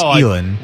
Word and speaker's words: Elin: Ellen Nordic? – Elin Nordic. – Elin, that's right. Not Ellen.